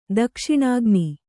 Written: ದಕ್ಷಿಣಾಗ್ನಿ

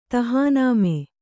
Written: ತಹಾನಾಮೆ

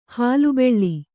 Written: ಹುಲಿಬಲೆ

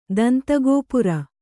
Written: ದಂತಗೋಪುರ

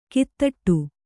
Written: ಕೈತಟ್ಟು

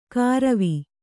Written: ಕಾರವಿ

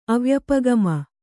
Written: ಅವ್ಯಪಗಮ

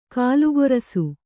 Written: ಕಾಲ್ಗೊರಸು

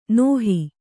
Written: ನೇಹಿ

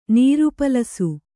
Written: ನೀರುಪಲಸು

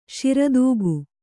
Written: ಶಿರದೂಗು

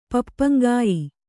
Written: ಪಪ್ಪಂಗಾಯಿ

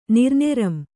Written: ನಿರ್ನೆರಂ

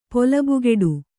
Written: ಪೊಲಬುಗೆಡು

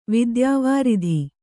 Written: ವಿದ್ಯಾವಾರಿಧಿ

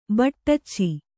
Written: ಬಟ್ಟಚ್ಚಿ

